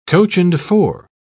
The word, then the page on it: coach-and-four: British English